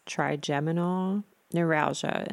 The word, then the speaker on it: trigeminal neuralgia: It has been crazy, and it's no fun to experience trigeminal neuralgia. Am I saying that right? – That's correct.